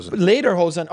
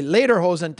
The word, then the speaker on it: Lederhosen: And when we return, I don't know if it's Lederhosen, how you say it. – Lederhosen. – Okay, Lederhosen. Thank you.